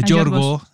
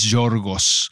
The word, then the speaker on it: Yorgos: Pero regresando a, Yorgo, Yorgo, no es Yorgos. Yorgo. – Es Yorgo, como se pronuncia correctamente.